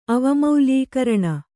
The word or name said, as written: ಅವಮೌಲ್ಯೀಕರಣ